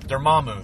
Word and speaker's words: Dormammu: Dormammu, thank you.